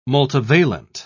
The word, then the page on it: multivalent: British English